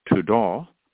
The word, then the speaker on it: todah: It is pronounced todah, which means an extension of the hand in worship, or a sacrifice of praise and thanksgiving.